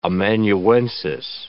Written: amanuensis

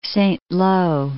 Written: Saint-Lo

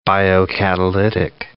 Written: biocatalytic

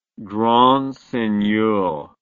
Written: grand seigneur

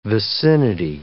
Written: vicinity

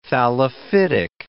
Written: thallophytic

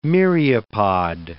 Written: myriapod

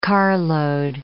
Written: carload

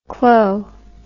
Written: clo

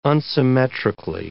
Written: unsymmetrically

